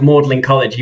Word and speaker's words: Magdalen College: But it's interesting because well, so I mean, that's why it's No, that's why it's Magdalen College, even though it's spelled Magdalen College, because the tradition of pronunciation far predates the spelling.